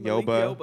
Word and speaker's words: Malik Yoba: maybe a day or two after another black trans woman was killed, Malik Yoruba, I think I'm saying his name right. – Did you say Malik Yoruba? – Is that not his name? – Malik Yoba. Yoba.